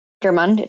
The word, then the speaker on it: Gjermund: Does that answer your question, uh, Ger- Gjermund? Am I? I hope I didn't yes. pronounce that right. Yes, yes, thank you. That's that's perfect.